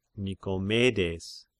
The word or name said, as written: Nikomedes